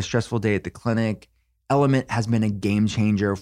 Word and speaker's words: LMNT: Have you heard about Element? It's spelled L-M-N-T.